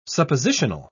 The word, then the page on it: suppositional: British English